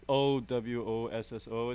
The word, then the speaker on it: Owosso: You've got a show Halloween week on October 27th in Oahu? – Owasso. – Yeah, but that's actually... – mispronounced that. – Yeah, how do you spell it, though? It's Owosso.